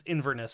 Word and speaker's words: Inverness: I was hunting up in the highlands about 50 miles north of Loch Lomond in Iverness. I probably sp- pronounced that wrong, but uh, it's Inverness, sorry.